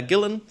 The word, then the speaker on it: Gillan: The amazing Dr. Claire Gillan.